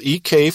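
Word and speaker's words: ek (ἐκ): Now that's spelled ek for ek, and kaleo for kaleo. And there's no line over that e, it's the short e.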